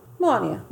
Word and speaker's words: Melania: I think I would fuck Melania. Or Melania. That's how you say it.